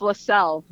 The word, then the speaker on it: Leczel: So that's spelled L-E-C-Z-E-L. Good luck. – Yeah. Say it again. L-E-what? – L-E-C-Z-E-L. – Is it Lexel? – Close. It's LaSalle.